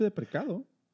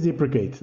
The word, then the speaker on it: deprecate: Yo siempre he dicho de precado. – Ese justamente está mal dicho. – ¿Ah, sí? – Sí. Eh, en inglés sí es deprecate, ¿no? – Deprecate, ajá.